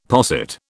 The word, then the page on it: posset: British English